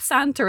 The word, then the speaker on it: Santr: I should say for listeners, the app Santa is spelled S-A-N-T-R. Santa.